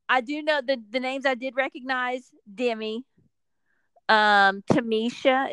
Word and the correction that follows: Tanisha: Is that how you say it? Tanisha. – Tanisha, the one that went to the end with Colton when he jumped the fence.